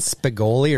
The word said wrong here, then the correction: Spicoli: or Spicoli? – Spicoli. – Thank you. – Oh, I was going G, wasn't I? Spicoli. – It was a hard G.